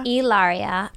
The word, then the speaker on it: Hilaria: Hilaria.